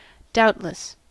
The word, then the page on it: doubtless: American English